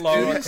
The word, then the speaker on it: Lara: Laura, is that her twin sister? – It's Lara Croft. – It had to be him. – That's how you say her name.